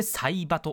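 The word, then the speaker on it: 彩葉: そして今週のおすすめグラビアはサイバ水木さんです西の葉っぱと書いてサイバと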